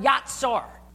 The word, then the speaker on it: yatsar: In the Hebrew, it's the word yatsar. And it's spelled Y-A-T-S-A-R.